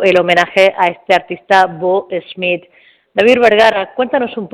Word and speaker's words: Boo: Bo, porque por algún motivo se escribe Bo y se pronuncia Bo,